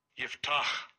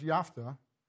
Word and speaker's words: Yiftach: I don't know how you get Yiftah out of that, but I'd always heard it pronounced Yaftah, but that's English, not Hebrew.